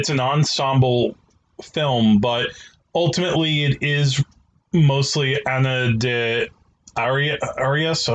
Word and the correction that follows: Ana de Armas: I'm sorry, Miss Ana de Armas.